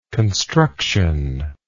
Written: constructionally

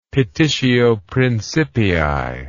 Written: petitio principii

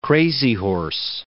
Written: Crazy Horse

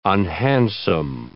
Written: unhandsome

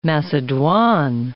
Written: macedoine

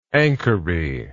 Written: anchory

